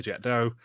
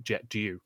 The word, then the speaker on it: Jet d'Eau: And it makes me laugh every time I see the Jet d'Eau. And I also do need to point out that only one person in this entire episode pronounced Jet d'Eau correctly, which was Flo, because not even Phil Cogan did.